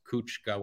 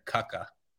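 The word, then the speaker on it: Kucka: pronunciation, I mean, the worst pronunciation I've ever done was last week for Juraj Kuchka when I said Kuka.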